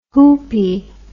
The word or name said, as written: hoopee